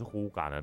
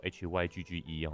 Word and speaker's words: hygge: 好，那什么是呼嘎呢？它其实写作 HYGGE 哈，它不叫 Hygge，它在丹麦那边念作呼嘎。